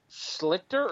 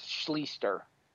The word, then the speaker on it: Schlichter: Schlichter or Schleister? – I think it might be pronounced Schleister. – S-C-H-L-I-E-S-T-E-R? – Not quite. I think it's S-C-H-L-I-C-H. Let me double check that. Yeah, S-C-H-L-I-C-H-T-E-R. – I believe it's pronounced Schleister.